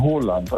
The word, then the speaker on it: Haaland: Er wird ausgesprochen Haaland, also mit O.